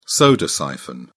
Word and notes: soda siphon: British English

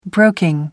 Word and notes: broking: American English

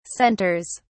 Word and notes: centers: British English

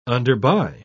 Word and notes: underbuy: British English

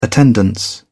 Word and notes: attendance: British English

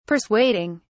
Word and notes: persuading: British English

